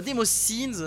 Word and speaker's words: Demoscene: Je parle de Low Cash Demosen. Enfin, Demosins, euh, Demosins, voilà, faut essayer de prononcer en, anglais, en anglais.